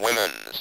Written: women's

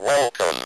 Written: welcome

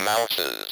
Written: mouse's

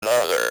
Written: another

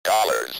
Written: dollars

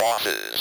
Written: bosses

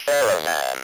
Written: caravan

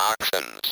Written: oxen's

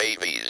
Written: baby's

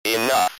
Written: enough

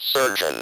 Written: surgeon

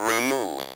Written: remove

